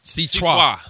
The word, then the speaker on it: C-Trois: Say Trios. – C-Trios. – Trois. – C-Trois. C-Trois. – You know, like Menage Trois.